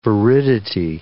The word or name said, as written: viridity